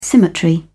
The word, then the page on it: symmetry: British English